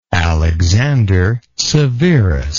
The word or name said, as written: Alexander Severus